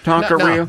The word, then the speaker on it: taqueria: Does it not look like taqueria? – No, it's taqueria.